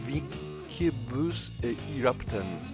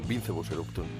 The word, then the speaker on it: Vincebus Eruptum: su primer trabajo que yo toda la vida he llamado Vincebus Eruptum, pero acabo de descubrir que se pronunciaría algo así como Vincebus Eruptum. O sea, voy a llamarlo como en pseudo latín, Vincebus Eruptum, no hay duda.